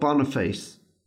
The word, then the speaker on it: Boniface: On May 13th, 1609 CE, Pope Boniface...